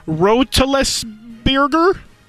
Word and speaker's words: Roethlisberger: Number 7 for those Pittsburgh Steelers, Big Ben Roethlisberger. That guy? – That's how you say it. That's how you say it. – Roteles Bierger.